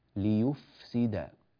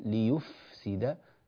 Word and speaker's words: ليفسد: يعني لا يقول ليف هكذا غير صحيح، بل ليفسد ليفسد هكذا من بطن الشفة.